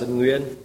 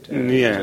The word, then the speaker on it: Nguyen: Is it pronounced Wen, Gwen? How do you pronounce it? – No, it's pronounced Nguyen. Nguyen.